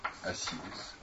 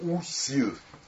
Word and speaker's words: Осирис: Осирис. Осирис – это греческая, греческизированная форма. В общем, по всей видимости, произносили египтяне это слово как усир.